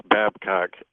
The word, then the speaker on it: Babcock: Look up my last name, two syllables, Babcock, and you'll get a good laugh out of it.